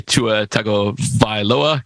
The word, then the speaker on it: Tagovailoa: That was Joe Burrow of Cincinnati, Tua Tagovailoa. Got to be careful when that was right. First time I've ever said it right.